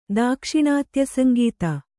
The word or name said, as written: ದಾಕ್ಷಿಣಾತ್ಯಸಂಗೀತ